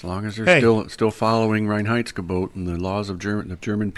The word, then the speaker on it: Reinheitsgebot: But, Reinheitsgebot. – Reinheitsgebot. – Reinheitsgebot. Very good. Sounded out three syllables.